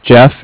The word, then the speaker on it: Jeff: So, once again, my name is Jeff, J-E-F-F, with Bank of Cardiff. Cardiff is spelled C-A-R.